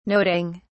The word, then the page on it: noting: British English